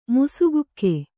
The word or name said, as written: ಮುಸುಕಿಕ್ಕು